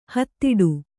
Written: ಹಿದೌಠ

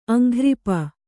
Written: ಅಂಘ್ರಿಪ

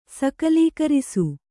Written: ಸಕಲೀಕರಿಸು

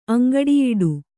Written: ಅಂಗಡಿಯಿಡು

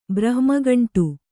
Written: ಬ್ರಹ್ಮಕಾಂಡ

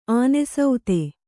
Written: ಅನಾಸ್ಥೆ

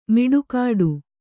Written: ಮಡಿಕಟ್ಟು

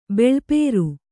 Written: ಬಿಳುಪೇರು

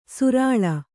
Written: ಸರಳಿ

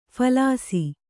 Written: ಫಲಾಸಿ

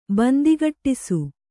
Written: ಬಂದಿಗಟ್ಟಿಸು

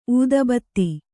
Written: ಊದಬತ್ತಿ

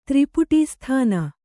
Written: ತ್ರಿಪುಟೀಸ್ಥಾನ

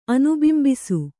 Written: ಅನುಬಿಂಬಿಸು

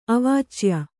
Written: ಅವಾಚ್ಯ

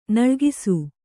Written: ನಲುಗಿಸು

